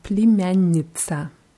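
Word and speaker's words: племянница: Ja, wird mit zwei N in der Mitte gesprochen und dann Iza, Plimjanica. – Plimjanica. – Ja, und das Je am Anfang PL. Je wird E wie I ausgesprochen. Plimjanica.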